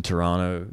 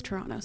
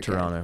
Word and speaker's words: Toronto: I'm up in Toronto this week, mm-hmm. promoting my record, playing a sold out show tomorrow night. – Pronounced it properly. You dropped the second T. It's Toronto. So you're Toronto.